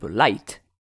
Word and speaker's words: LITE: Alors, qu'est-ce que c'est que le groupe Light? L-I-T-E, ou Light, peut-être, et quand je le prononce Light, je pense que ça parlera à certains, c'est un groupe japonais.